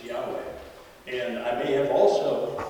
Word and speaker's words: Yahweh: And so they thought it was Jehovah. But more recent scholarship says that they believe it was pronounced Yahweh.